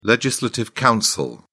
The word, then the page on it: legislative council: British English